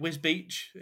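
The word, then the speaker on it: Wisbech: I know Cubit, Wisbeach, even though it's spelled Wisbeck. – No, it's Wisbeach. – No, it's Wisbeach. – But it shouldn't be Wisbeach because it doesn't spell beach.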